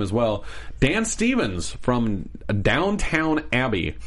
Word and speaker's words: Downton: It's Downton Abbey.